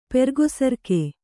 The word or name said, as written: ಪೆರ್ಗೊಸರ್ಕೆ